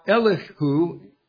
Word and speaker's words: Elihu: Now, Elihu would be the proper Hebrew pronunciation.